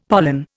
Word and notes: pollen: Indian

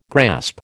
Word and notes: grasp: American English

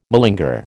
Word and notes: malinger: American English

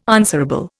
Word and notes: answerable: Indian

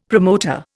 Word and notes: promoter: Indian

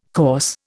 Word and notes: coarse: Indian

